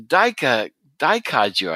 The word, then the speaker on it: daikaiju: Unfortunately for the bots, the planet is infested by kaiju and daikaiju, I guess it's pronounced. – Yes.